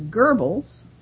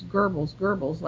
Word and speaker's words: Goebbels: And it's very common for English speakers to pronounce it as Goebbels, and you get carried away, and pretty soon you're sounding very English. There's no German to it. It's Goebbels, like a gerbil.